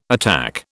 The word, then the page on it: attack: British English